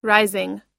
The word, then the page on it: rising: i